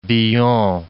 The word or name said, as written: Villon